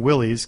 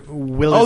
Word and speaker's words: Willys: Wait, so the people who made Jeeps, is that pronounced Willys? – Yes, sir.